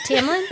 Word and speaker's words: Tamlin: Wouldn't mind a tampon redemption arc. – Did you say tampon? – Oh, was it Tamlin? – Oh, I'm sorry.